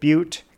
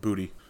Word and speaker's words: Butte: I picked this up when I was in the great mining city of Butte a couple weeks ago. – It's pronounced booty. – Oh, I thought you were going to say but. That's usually the joke you make.